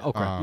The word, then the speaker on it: Okra: I don't know if I'm pronouncing that right, but it's spelled E-A-T-O-K-R-A. – Yeah, okra. – Yes, okra.